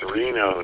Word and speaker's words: Reno: Yeah, it's Reno, Nevada. Long E.